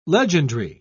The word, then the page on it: legendry: British English